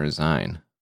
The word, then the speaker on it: zine: Zine or Zine? – Zine. – Isn't that weird? Because it's magazine.